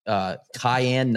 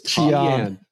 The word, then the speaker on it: Cheyenne: The first tee announcer hit it with, I think it was like Cayenne Knight, or no, you know, it was Cheyenne. – We weren't even close.